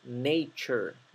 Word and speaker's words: nature: La palabra naturaleza, nature, recuerden que T-U-R-E se pronuncia chur. Chur, ¿sí? Hay muchas palabras que tienen esta terminación, chur, y no la pronuncian nature o nature o nature, es nature, nature. La terminación ture es chur.